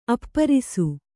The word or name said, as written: ಅಪ್ಪರಿಸು